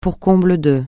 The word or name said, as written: pour comble de